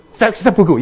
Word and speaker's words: seppuku: Not harikari. What is it? How do you say in Japanese? Seppuku.